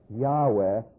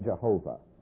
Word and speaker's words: Yahweh: Now, I Am is simply the little, called the Hebrew tetragrammaton, the letters Yod, He, Wow, He, which are pronounced Yahweh, or I'll put parenthetically, because we're probably more accustomed to it, Jehovah.